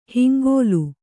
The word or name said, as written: ಹಿಂಗುಲ